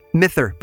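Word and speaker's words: mither: Mithr, by the way, is the Orcadian pronunciation of the word mother.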